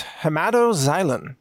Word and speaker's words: hematoxylin: The second one is a blue dye known as hematoxylin. That's spelled X-Y-L-I-N at the end there. Hematoxylin.